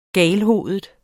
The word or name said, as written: galhovedet